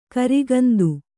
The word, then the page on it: ಖರಗಂಧ: kharagandha; Kannada